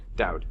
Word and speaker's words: Daoud: Do I say your last name Daoud? – Daoud. – Okay. – It's Arabic for David, actually.